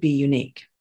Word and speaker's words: bUneke: And long story short, we created bUneke. And because we decided it had to be unique, we spell it B-U-N-E-K-E, which is emphasizing the U.